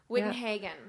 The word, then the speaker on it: Wittenhagen: Okay, well, today we are here with Emily Wittenhagen. Did I say that right? – Wittenhagen. – Wittenhagen. Yeah.